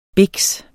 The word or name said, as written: BIKSEN